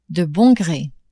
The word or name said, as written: de bon gre